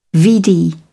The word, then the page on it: vd: British English